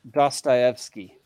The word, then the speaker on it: Dostoevsky: And it's pronounced Dostoevsky, just so everybody knows.